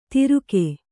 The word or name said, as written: ತಿರುಕೆ